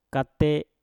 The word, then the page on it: kate: Mpur